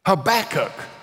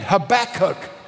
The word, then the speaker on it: Habakkuk: Habakkuk. Would you say that with me? Fair. Let's say it again Habakkuk.